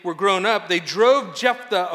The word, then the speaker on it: Jephthah: Everybody say Jephthah. Yeah, you got a little bit of the lisp thing. Jephthah, okay? And that's how we're going to pronounce it here.